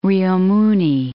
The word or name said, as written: Rio Muni